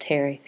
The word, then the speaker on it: Terje: Heard from Terry, who let me know that her name, which is spelled T-E-R-J-E, is actually pronounced Terry.